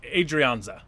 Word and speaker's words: Adrianza: So what I spelled like Adrian and then Za. – Yeah, so Adrianza. – Yeah, it's probably Adrianza.